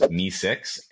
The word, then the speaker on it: Mee6: So number one, probably my favorite on the list, is Mee6. It's spelled M-E-E-6.